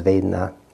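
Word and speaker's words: Vedanā: So, the, uh, the word there, um, you wouldn't have known this, but the, uh, the, the, actual pronunciation is Vedna, um, pleasant Vedna, Sukha Vedna.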